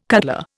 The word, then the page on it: cutler: Indian